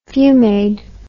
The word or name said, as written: fumade